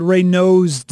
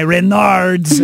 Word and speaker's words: Raynaud's: Found out you have I have something called Raynaud's disease. I just looked it up, and thank you on the text line because they were laughing at my Renard's disease.